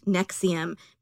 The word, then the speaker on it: NXIVM: ESP was part of a larger company called Nexium, but it's spelled...